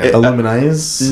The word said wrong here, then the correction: Illumise: No, Illumise.